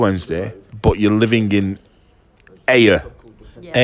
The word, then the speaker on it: Ayr: Is that how you pronounce Ayr? – A-Y-R. Ayr. – Is that how they pronounce it? In Ayr? Is it Ayr? – Yes. – Ayr. Ayr. – Pronounced it right.